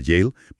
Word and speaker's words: Yale: Y aquí entra un estudio muy reciente de la Universidad de Yale, que se pronuncia Yale, publicado en la revista Proceedings of the National Academy of Sciences.